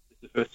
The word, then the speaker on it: Sisyphus: I don't know how if I'm pronouncing it properly, but Go Sisyphus? – Sisyphus, yeah.